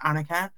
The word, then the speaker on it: Anyika: So there's an NW before it, which is like basically like Anyika, which is the full pronunciation.